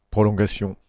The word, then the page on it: prolongation: [pʁɔ.lɔ̃.ɡa.sjɔ̃]